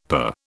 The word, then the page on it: per: British English